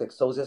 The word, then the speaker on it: Exousia: Exocia, estoy eh, pronunciándolo bien el nombre del disco. Sí, correcto. Exousia es una palabra en griego que tiene que ver con fuerza, autoridad, poder.